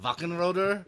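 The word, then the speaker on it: Wachenröder: He worked on the game for Sega Saturn, Wackenroder. I'm saying that right. Wackenroder would probably be right. – Yeah, well done, man. – Because it's German.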